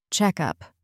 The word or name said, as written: checkup